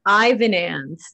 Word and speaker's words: Ivanans: It's Ivanans.